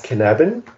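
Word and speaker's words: Cynefin: Ja, also ich arbeite gerne mit einem Framework, das heißt Canabin. Bitte nicht googeln, das schreibt man so anders, als man sagt. Ist nämlich ein walisisches Wort von Dave Snowden.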